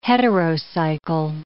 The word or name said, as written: heterocycle